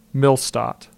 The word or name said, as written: Millstadt